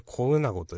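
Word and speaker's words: 小女子: あと、漢字では、小さい、大きい、大小の小に、女子、女の子供と書いて、コうなごと読むんですけど。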